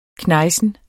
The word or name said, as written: knejsen